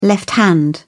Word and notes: left hand: British English

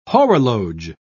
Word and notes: horologe: British English

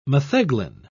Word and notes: metheglin: British English

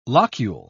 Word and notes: locule: British English

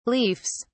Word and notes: leafs: British English

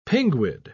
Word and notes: pinguid: British English

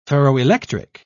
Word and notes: ferroelectric: British English

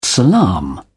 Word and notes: salaam: British English

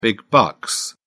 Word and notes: big bucks: British English

